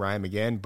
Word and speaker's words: Ryan Magin: My Instagram is my name, Ryan McGinn, but... – How do you spell that? – R-Y-A-N-M-A-G-I-N.